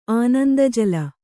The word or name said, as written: ಆನಂದಜಳ